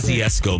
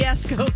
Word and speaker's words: Kosciusko: Winfrey is giving monies to Chicago, Baltimore, Nashville, Milwaukee, and in uh, Kosciusko, Mississippi. I might not be saying that right. I apologize. – Kosciusko, Mississippi. Kosciusko.